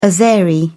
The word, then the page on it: azeri: British English